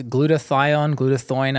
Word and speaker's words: glutathione: So what we're going to touch on next is glutathione.